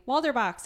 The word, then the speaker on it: Walderbach: Hi, Walderbach.